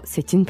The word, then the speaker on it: c'est une: Yeah, c'est is followed by a vowel word. You pronounce the T at the end. So, c'est une plante. That's why.